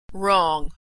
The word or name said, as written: wrong